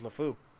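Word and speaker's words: LeFou: But there was other scenes with that same guy or with I always say his name wrong. LaFouche. Huh? – LaFou, I think. I think it's just LaFou.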